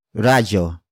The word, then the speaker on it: radyo: Radio. Radio. Radio is spelled as R-A-D-Y-O.